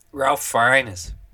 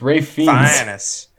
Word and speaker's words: Ralph Fiennes: Ralph Fiennes. – No, it's Ray Fiennes.